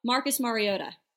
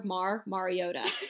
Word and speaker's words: Mariota: Marcus Mariota. All right, there you go. Yes. Mar, like in car, Mar Mariota.